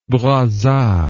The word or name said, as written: Brazza